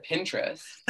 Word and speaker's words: Pinterest: I've always said Pinterest. – That's what I've said. – Like interest and you pin it, like Pinterest. – Right. That's what I thought. But like, spelling it out, it is Pinterest.